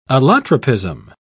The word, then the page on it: allotropism: British English